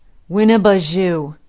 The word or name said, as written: winneboujou